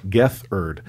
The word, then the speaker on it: Gethard: On Beautiful Stories from Anonymous People, comedian Chris Gethard, and I have to pronounce it here, Gethard, talks to one anonymous caller for an hour.